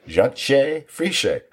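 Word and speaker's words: Jantje Friese: That one I actually wrote down phonetically so I wouldn't screw it up. So it is. It's Yanshe Frishe. Frishe. This is not how the name is spelled.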